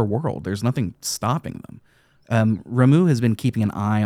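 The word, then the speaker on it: Ramuh: An Esper whose name I learned to pronounce correctly after I started learning Japanese. It is Ramu, not Rama.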